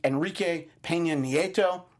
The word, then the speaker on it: Enrique Peña Nieto: He meets with Mexican President Enrique Peña Nieto. I'm sure I nailed that pronunciation. – You did, absolutely.